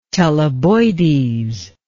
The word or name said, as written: Teleboides